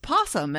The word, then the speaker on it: opossum: oh, I don't even remember what it was, but somebody was insisting that I was saying it incorrectly and that it was just possum. And I was like, well, why does it have an O on the front? Hmm. There's no such thing as a silent O, except there was a Sesame Street story song about that, wasn't there?